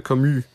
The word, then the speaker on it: Camus: É, Camus, pelo menos eu acho que eu sei pronunciar certo, né? Camus, mas é, se pronuncia Camus.